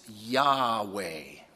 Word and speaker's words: Yahweh: Some have suggested Yehovah or Jehovah. Others say it's Yahweh. And that's the pronunciation that I agree with because it's very close to another Hebrew word in the Old Testament, which means to be. Awa, Yahweh, to be, I am.